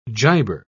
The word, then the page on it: giber: British English